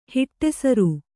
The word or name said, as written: ಹಿಟ್ಟೆಸರು